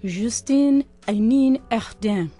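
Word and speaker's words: Justine Henin-Hardenne: And Mike, just a note, I believe in Belgium is pronounced Justine Henin-Hardin.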